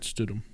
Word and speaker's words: Stidham: Stidham? – Mm-hmm. Jarrett Stidham.